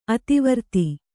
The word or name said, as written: ಅತಿವೃತ್ತ